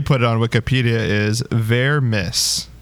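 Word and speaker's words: Vermes: But okay, I am on the Wikipedia page, and Vermees, the accent is on the Mies, so it is Vermees, according to Wikipedia.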